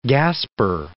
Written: gasper

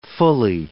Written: fully

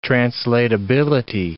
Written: translatability

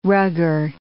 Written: rugger

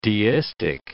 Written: deistic

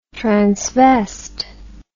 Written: transvest

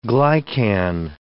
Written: glycan

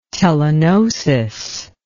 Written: telegnosis

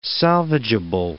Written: salvageable

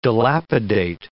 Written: dilapidate

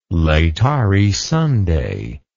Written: Laetare Sunday

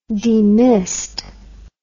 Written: demist

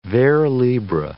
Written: vers libre

